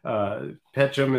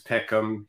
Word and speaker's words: Pecham: So Pecham is one. Also variously pronounced as Pecham. It's you're in good company with either.